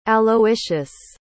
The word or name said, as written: aloysius